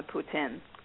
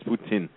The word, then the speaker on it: poutine: Poutine. – Apparently, they're telling me it's poutine, but I said poutine because it's I-N-E.